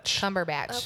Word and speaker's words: Cumberbatch: Cumberbatch.